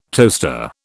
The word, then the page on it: toaster: British English